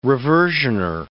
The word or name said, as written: reversioner